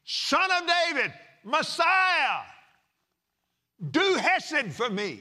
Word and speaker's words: Hesed: And I didn't know how to pronounce it, but it's Hesed. It's actually Hesed.